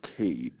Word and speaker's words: Kade: My name's Cade, so growing up, nobody could pronounce my name right. They just said Katie. – It's like, Cade? Oh, okay. K-A-D-E.